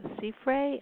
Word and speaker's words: Siffre: Yeah, the writer says it's L-A-B-I, Labi, and then Sifre, S-I-F-F-R-E. – Mm-hmm. – Am I pronouncing it correctly?